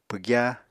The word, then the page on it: pwgya: Apatani